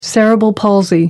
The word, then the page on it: cerebral palsy: British English